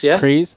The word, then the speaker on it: Kryze: How do you pronounce it? – Kryze.